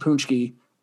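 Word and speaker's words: pączki: The Polish language is fantastic because that's spelled P-A-C-Z-K-I and is pronounced Punchki, which I I can't explain that to anyone.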